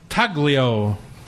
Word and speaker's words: Taglio: Brendan is the chef owner of Hitchcock and now the new Bar Taglio. Like the train. – The G is silent like lasagna.